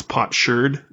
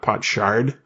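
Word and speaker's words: potsherd: like it's pot sherd, not pot shard but shard.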